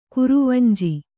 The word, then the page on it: ಕುರುವಂಜಿ: kuruvanji